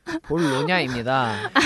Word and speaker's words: 볼로냐: 실제로 이번 전자책 같은 경우는 올해 아동 도서 분야 최고의 건의상인 볼료, 아, 볼로냐? – 볼로냐입니다. – 아, 네.